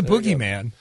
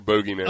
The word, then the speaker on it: bogeyman: There's actually two different pronunciations. – You can pronounce it. I'm looking at it right now. Boogeyman or bogeyman.